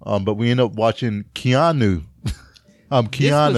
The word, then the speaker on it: Keanu: That's how you say it. Keanu.